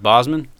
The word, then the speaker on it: Bosman: Is it Bosman? Like Bosman. A Z? – Like a Z, yeah.